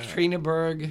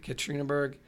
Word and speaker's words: Ekaterinburg: The Romanovs were taken to, if I'm pronouncing this right, I'm sure you're not. – Ekaterinburg. – Yeah. – Is that right? Ekaterinburg, which was the historic hub of Russia's old penal system in the penal.